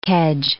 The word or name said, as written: kedge